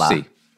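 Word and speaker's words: Jussi-Pekka: Oh, it's Yussi. Okay, yeah. I was questioning because sometimes the U is silent or sharp in different countries. – Yes. – You know what I'm saying? And they put two U's to say Yussi. In this one, they put two S's.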